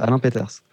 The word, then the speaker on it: Alain Peters: Ah, je prononce peut-être mal d'ailleurs, mais. Non, non, c'est ça, c'est exactement ça. Alain Peters.